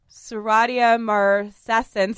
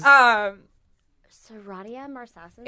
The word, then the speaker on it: Serratia marcescens: And this is also this is why your shower gets so like quickly moldy and mildewy and Serratia marcescens. You guys, I said it right on the first try. – There it is. – Serratia marcescens.